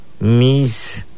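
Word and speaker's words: miis: Gato lo pronunciamos de esta manera. Mis, así como se da. Mis, es gato en lengua maya.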